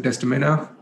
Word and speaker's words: Desdemona: Uh, Kareena Kapoor's Dolly is Desdemona.